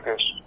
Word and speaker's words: Kesh: Es Kesh, se escribe con K y SH al final, de Kesh, como suena, y significa mirar, en tehuelche, ¿sí?